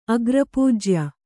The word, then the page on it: ಅಗ್ರಪೂಜ್ಯ: agrapūjya